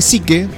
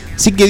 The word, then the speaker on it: Psique: Eh, confirman que el asteroide, se debe decir Psique, porque se escribe. – Sí. – Psique 16 vale más que toda la economía mundial.